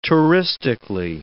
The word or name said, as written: touristically